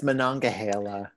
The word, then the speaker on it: Monongahela: Monongahela, Pennsylvania. – Oh, come on, girl. Yeah. No, it's Monongahela.